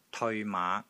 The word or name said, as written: TEOI3 MAA5